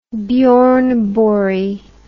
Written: bjorneborg

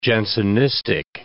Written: Jansenistic

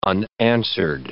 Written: unanswered

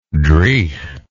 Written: driegh

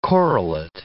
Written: correlate

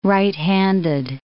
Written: right-handed

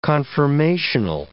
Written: conformational

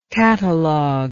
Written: cataloguist

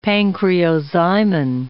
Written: pancreozymin

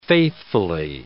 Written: faithfully

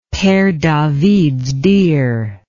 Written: Pere Davids deer

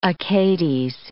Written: Achates

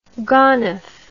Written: gonov

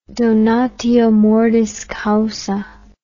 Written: donatio mortis causa

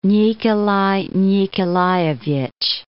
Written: Nikolay Nikolayevich

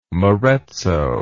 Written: marezzo

